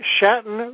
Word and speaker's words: Chatan: And now I call on Greg Chatan, rhymes with Manhattan. Is that correct, Greg? – That's correct.